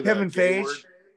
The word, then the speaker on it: Kevin Feige: I want to say Kevin Feige.